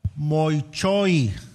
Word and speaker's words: moichoi: Adulterio, eh, diga conmigo, moichoi, moichoi, como muchoi, así como muchoi, moichoi, así se pronuncia muchoi.